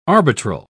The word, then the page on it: arbitral: British English